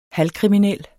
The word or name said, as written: halvkriminel